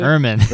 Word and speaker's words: Ermine: Number 11 is Lady with an Ermine, or Ermine. I don't know how you pronounce it. – Ermine. – Ermine, there you go.